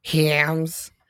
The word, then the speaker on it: Hyams: if it matters to anybody, it was directed by John Hams. It's like H Y A M S, and I just want it to be pronounced that way.